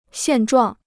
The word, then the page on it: 现状: xiàn zhuàng